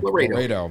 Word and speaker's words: Laredo: I know the car. I can't pronounce Laredo. – It. – Laredo. – Like Texas.